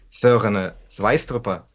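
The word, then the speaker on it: Søren Sveistrup: А говорим мы сегодня о книге Сёрена Свайструпа. Вот именно так правильно его следует произносить.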